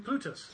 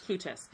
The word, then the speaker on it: Plutus: Yeah Plut- uh, sorry, what, what was the Greek pronunciation? Plutus. Plutus.